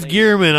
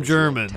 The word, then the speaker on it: Gearman: Um, Apparently, it's Gearman. I was calling him German.